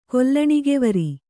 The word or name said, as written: ಕೊಲ್ಲಣಿಗೆವರಿ